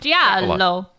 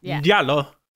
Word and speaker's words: giallo: Giallo. – Giallo. – Not to be confused with jello.